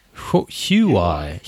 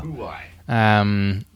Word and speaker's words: Huawei: Huawei. – Wow. – Huawei.